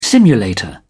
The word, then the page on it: simulator: British English